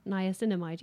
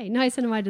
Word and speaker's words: niacinamide: Um, the super pure, which is niacinamide, which I can never pronounce, but it's basically just ni- niacinamide. Yeah, I said it right. Yeah, niacinamide and zinc.